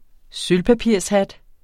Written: sølvpapirshat

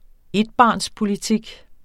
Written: ETBARNSPOLITIK

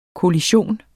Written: kollision

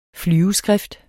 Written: flyveskrift

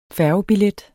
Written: færgebillet

